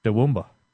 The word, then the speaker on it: Toowoomba: And you pronounce it like this, Toowoomba, or better, more appropriately, in a mid-Queenslandic Australian accent. You say, Toowoomba.